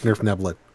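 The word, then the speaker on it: Svirfneblin: Yes, it's pronounced Nerfneblin.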